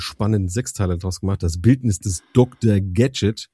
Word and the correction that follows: Gachet: wenn ich das richtig ausspreche, Gachet. – Das Französische.